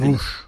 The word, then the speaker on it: Rouch: Daha sonra 1961'de etnolog ve yönetmen kim, Jean Roche mu, nasıl okunuyor? – Jean Rouge.